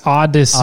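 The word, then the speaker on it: Audacy: And that's what you were talking about, that other episode, a few episodes back, where you're like, oh, the new audio thing, Audacity. – Yeah. – So it's Odyssey. – Odyssey. – Odyssey, but it's spelled weird. – A-U-D. – A-C-Y. Yeah.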